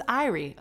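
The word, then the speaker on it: Aire: And it's not even pronounced air. When I walked in, the lady's like, welcome to Irie. And I was like, it isn't. No, you got to be saying it wrong. It's air. No, it's Irie. I don't think so. It's not I-R-I-E. It's A-I-R-E.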